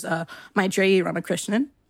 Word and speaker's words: Maitreyi Ramakrishnan: which is Maitreyi Ramakrishnan.